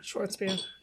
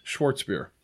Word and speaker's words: Schwarzbier: Schwarzbier. – Schwarzbier.